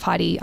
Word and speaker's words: Heide: I did learn, I gave it the old German pronunciation of Heide, so I've since learnt the institution of Heidi Museum of Modern Art's actually quite an incredible place.